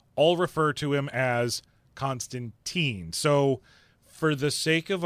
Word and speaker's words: Constantine: Or technically, if you live in Great Britain, they will tell you, you're saying it wrong. It is Constantine.